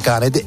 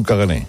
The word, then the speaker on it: caganer: Es, iba a lleva R, cagané, pero no se pronuncia. – ¿Y entonces para qué la ponen? – Bueno, pues pasa con muchos idiomas. Hay muchas letras que van, pero no se pronuncian. No es cagané. Sí, no. En todo caso sería caganer, vale. Pero la R no se pronuncia, es un cagané.